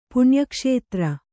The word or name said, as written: ಪುಣ್ಯಕ್ಷೇತ್ರ